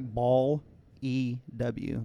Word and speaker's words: Ballew: B-L-E-W? – Luann. No, it's like Ball E-W. Like Ball, ooh. But I'm saying Baloo because I'm imagining it's pronounced Baloo. – So it's B-A-L-E-W? – B-A-L-L-E-W.